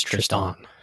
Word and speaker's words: Tristan: It's spelled like Tristan Harris, but it's Tristan.